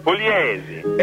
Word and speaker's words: Pugliese: Jim Pugliese, am I saying his name right? – Pugliese.